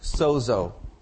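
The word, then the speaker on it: sozo (σῴζω): It's a word pronounced sozo. Sozo.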